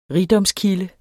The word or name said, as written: rigdomskilde